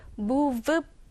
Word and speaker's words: move: Anyway, 다시 돌아와서 여기에서 move라는 이 단어, v 사운드를 볼게요. 자, v 사운드는요, 우리나라에는 없는 발음이죠. 그렇죠? 그래서 앞에 우리 지금 입 안의 구조를 봤을 때 앞니가 아랫 입술을 살짝 물면서 그리고 소리가 유성음이 나야 돼요.